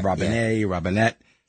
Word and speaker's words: Robinette: And it turns out his middle name is Robinette. – Yeah. – I don't know if I'm saying that right. – You are, Robinette.